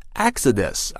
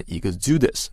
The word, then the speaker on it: Exodus: The reasons for the exodus include high inheritance taxes and Brexit。这些超级富豪大批离去的原因啊，包括这个高遗产税，还有英国脱欧而产生的连带作用。这句话进一步就解释了啊，前面的两个现象分别是怎么产生的。 Exodus 啊，这个词注意发音，不念 exodus，念 Exodus。